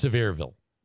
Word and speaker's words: Sevierville: That's actually pronounced Sevierville. – Is it? – Or Sevierville, as they say there, Sevierville.